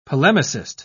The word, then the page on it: polemicist: British English